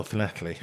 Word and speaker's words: Llanelli: Llanelli.